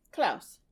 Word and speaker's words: Klaus: My second one would be, I don't know if it's pronounced Klaus or Klaus. – It's on Netflix? – Yeah. – Klaus.